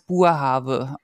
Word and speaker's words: Boerhaave: So, und bei der Niederländer, weil spricht man es Boerhaave aus und nicht Boerhaave, so wie wir das immer falsch machen irgendwie.